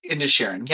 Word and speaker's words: Inisherin: Of Inisherin. Yeah, I said that right.